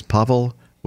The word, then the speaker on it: Pavel: His name is Pavel Winierski. The W in his first name is pronounced like a V.